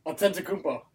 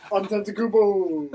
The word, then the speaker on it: Antetokounmpo: Antetokounmpo. – Just did it. Giannis! – Antetokounmpo.